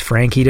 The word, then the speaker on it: Francci: I hope I'm saying that right. It's like F-R-A-N-C-C-I. That's Frankie to me.